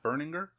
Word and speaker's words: Berninger: Is that how you pronounce his name? Matt Berninger? – Sure.